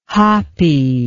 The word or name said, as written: hapi